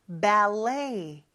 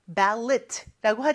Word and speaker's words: ballet: 자, 발레는 영어로 ballet 이렇게 발음을 합니다. 근데 끝에 t가 있죠? ballet라고 하지 않아요. 이거는 프랑스라는 나라의 언어에서 온 말이기 때문에 그 나라의 언어는 마지막 T를 발음을 안 하거든요. 그래서 그거를 그대로 가져온 거죠. 그래서 ballet 이렇게 발음합니다.